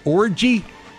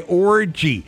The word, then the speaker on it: Orji: Orgy? – That's how it's spelled. – O-R-J-I, Orgy.